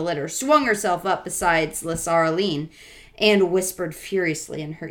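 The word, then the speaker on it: Lasaraleen: way for the Tarkheena Las- Lasarine. – Lasaraline, or Lasaraline? – La- yes, there's another syllable in there.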